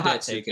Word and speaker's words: Datsyuk: Datsyuk era, in the Zetterberg era that we actually ended up with. – No, you said it correctly, man. It was the Datsyuk era.